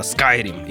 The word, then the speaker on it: Skyrim: Я, ладно, ладно, Сережа, я не поправлял тебя на Skyrim, потому что правильно Skyrim.